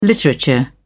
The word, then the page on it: literature: British English